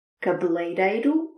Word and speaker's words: cabeleireiro: Cabeleireiro. Cabeleireiro. Dois R's suaves. Muito suaves. Reiro. Reiro. Cabeleireiro. Cabeleireiro.